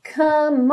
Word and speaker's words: come: Começando então, come on, baby, come on. Percebe como que essas duas palavras são ligadinhas, como é do come, não é pronunciado, você já liga direto esse M no on, então come on, let's do the twist.